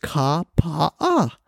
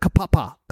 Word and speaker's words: Kapaa: So Kapaa is pronounced Kapaa. I always had trouble with that because when I first got here to Hawaii, back in 92 or 93, and then they had the hurricane. I was the first reporter over to Kauai, and I spent a lot of time in Kapaa. I always had trouble pronouncing Kapaa for some reason. I called it Kapapa, Kapaa, Apa.